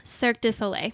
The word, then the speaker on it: Cirque du Soleil: Kelsey T, they go on a Cirque du Soleil or how do you say that? – Cirque du Soleil.